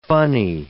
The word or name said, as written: funny